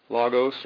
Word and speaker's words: logos: Logos or logos, depending on how you pronounce it.